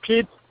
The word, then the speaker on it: Pete: Yes, it's Pete, and last name is Sinju, S-I-N-J-U. – Okay, so Pete as in P-E-T-E, right? – Yes. – And then Sinju, S-I-N-J-U? – Correct.